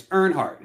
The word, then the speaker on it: Earnhardt: I said the name wrong. It's Earnhardt. It's Dale Earnhardt and Al.